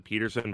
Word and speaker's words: Pettersson: but Ilias Pettersson or Peterson, like the guy himself seems to pronounce it Peterson, but everyone else calls him Pettersson.